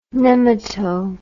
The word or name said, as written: nemato